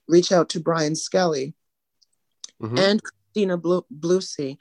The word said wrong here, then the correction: Blossy: I hope I'm pronouncing her name right. Blossy? – Uh, Christina uh, Blossy for her.